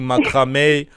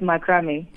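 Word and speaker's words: macramé: Yeah, I hope, Olerato, that you will not turn me into a wall hanging for not pronouncing macrame, macrame. – Oh, my gosh. – So give us the correct pronunciation. – It's macrame.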